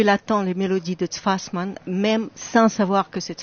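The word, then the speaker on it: Tsfasman: D'ailleurs, le pari est réussi parce que on a entendu cet enthousiasme du public pour ce Tfasfan qui est si difficile à prononcer pour nous Français. – Tfasman, Alexandre Tfasman,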